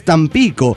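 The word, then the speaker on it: Tampico: no lleva tilde, por lo tanto se acentúa en la I, entonces es Támpico. Y bueno, y tú preocupado por estupideces, dice el subsuelo. Es Támpico, efectivamente es Támpico, Támpico Tamaulipas, pero no sé, me gustó como suena Támpico, póngale.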